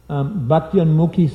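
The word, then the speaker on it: Bhaktyunmukhi: Okay, so there's one that leads to bhakti that's called unmukya. Let's see, how's it pronounced again? Bhaktyanmukhi sukriti.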